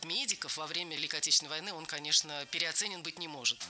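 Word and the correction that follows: переоценён: Переоценен. Неверно. Ударение в этом слове следует ставить на последний слог, в котором должна быть наша любимая «ё». Переоценен.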